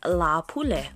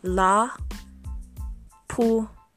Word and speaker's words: Lāpule: It is Lāpule, and Lāpule is spelled L A Kahako P U L E. Lāpule. Pronounced, repeat after me, La Poule.